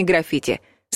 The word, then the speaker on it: граффити: И в-третьих, ударение падает на второй слог. Граффити.